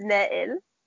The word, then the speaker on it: Nail: His name is Nail. Yeah. It's two syllables, girl.